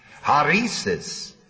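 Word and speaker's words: hairesis: Comes from the word spelled H-A-I-R-E-S-I-S. H-A-I-R. How would you pronounce it? Haresis.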